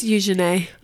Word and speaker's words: Eugenie: May I correct you? – Oh, Beatrice. – It's Eugenie. – Okay. – Hey, that's how you pronounce her name.